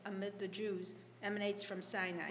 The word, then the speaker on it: Sinai: Yeah, I think the Hebrew pronunciation of Sinai is almost identical to the Hebrew word for hatred, Sinah.